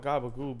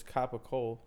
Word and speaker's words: capicola: Yeah, I call it gabagoo, but it's capicola.